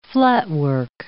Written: flatwork

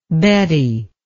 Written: Bette

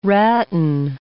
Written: ratton

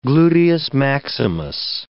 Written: gluteus maximus

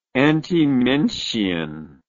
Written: antimension

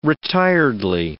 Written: retiredly

